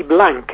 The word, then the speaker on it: Blanc: But it's made from it's made by Raventos y Blanc. You do pronounce it as a blanc, in actual fact, in Catalan.